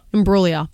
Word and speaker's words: Imbruglia: Soft G. – Soft G. – Imbriglia. – Soft G. – Imbriglia. – Bang. – Imbruglia. Natalie Imbruglia. – Beautiful.